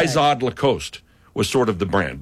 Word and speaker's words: Lacoste: Lacoste.